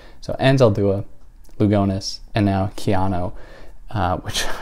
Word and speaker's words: Anzaldúa: And I did all this work trying to find out how to pronounce Anzaldúa, and I was consulting all of these Spanish sources, but the problem was I was consulting like Spanish Spain sources who pronounced her name something like Analúa.